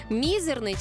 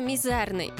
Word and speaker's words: мізерний: Отож, давай розбиратися із словом «мізерний» чи «мізерний». Не сперечайтеся, словники офіційно дозволяють обидва варіанти. Вони рівноправні, але мають геть різний сенс.